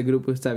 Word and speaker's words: grupo está: It's a huevo este grupa este bien chingón. – So what the correct pronunciation is a huevo este grupo está bien chingón, which means basically like, fuck yeah, this band is amazing.